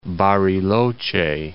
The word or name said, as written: Bariloche